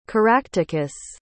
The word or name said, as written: caractacus